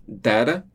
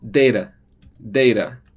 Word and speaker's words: data: E outra coisa que é importante, você tem data, que pode ser pronunciado assim, né? Tipo, algo como com esse som de E, assim, data. Mas você também pode falar data, data, com esse som de E. São duas pronúncias corretas, duas pronúncias que você vai encontrar.